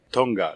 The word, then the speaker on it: Tonga: Tonga, like the islands.